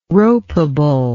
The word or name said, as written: ropable